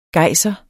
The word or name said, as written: GEJSEREN